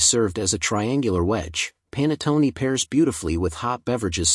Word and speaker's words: panettone: The word panettone, meaning a large cake, is pronounced as panettone, and its history dates back to the Roman Empire.